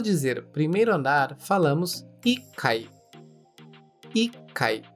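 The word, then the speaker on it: 一階: Com atenção, pois apesar do número 1 um ser, de modo geral, Ichi, neste caso específico, a sílaba Ti é retirada e colocado o Ti tsu que é aquele Tsu pequeno, indicando uma pausa rápida antes do Kai, ficando Ikai.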